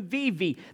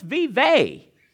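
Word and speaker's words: Vevay: We're from Vivi.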